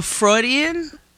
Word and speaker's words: Freudian: I was like, but Freudian? Is that how you say it? – Yes, that was an album.